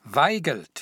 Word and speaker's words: Waigelt: Weigelt, Frank. W-A-I-G-E-L-T.